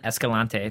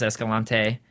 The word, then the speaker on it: Escalante: And also, we noticed the whole time that they are trying to get on Escalante about what's going on. Escalante, thank you. For some reason, I thought it was spelled with a D, but they always pronounce Escalante.